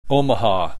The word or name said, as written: Omaha